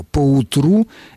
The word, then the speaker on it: поутру: Поутру – это наречие, которое пишется слитно, и ударение имеет, как мы видим, на последнем слоге.